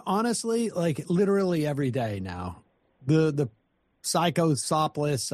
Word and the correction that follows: cyclospora: I can't pronounce it, is still cyclospora.